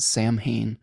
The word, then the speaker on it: Samhain: Lynn says, the traditions of Samhain, and I think Samhain is the way to pronounce it. Some people pronounce it Samhain, but I think it's Samhain. And All Hallows have more to do with honoring the dead than gory stuff.